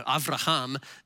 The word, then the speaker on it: Avraham: Avraham is how you really pronounce it.